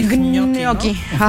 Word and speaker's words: gnocchi: Ah, sí, gnocchi, ¿no? Ajá. – Sí, sí, sí. – Que hace el mismo sonido, la gn en italiano, que la ñ.